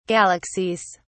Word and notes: galaxies: British English

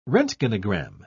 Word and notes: roentgenogram: British English

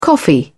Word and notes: coffee: British English

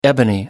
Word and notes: ebony: American English

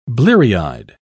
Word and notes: bleary-eyed: British English

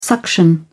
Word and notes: suction: British English

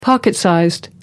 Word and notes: pocket-size: British English

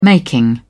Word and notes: making: British English

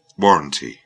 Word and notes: warrantee: British English

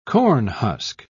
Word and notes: cornhusk: British English

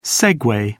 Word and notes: segway: British English

